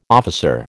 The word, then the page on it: officer: American English